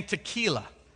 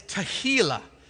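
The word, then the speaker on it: tehillah: Now, it's very important that you pronounce that correctly. I don't want you saying tequila. All right? Because if you sing after tequila, I doubt it's going to be worship. All right? It's tahila.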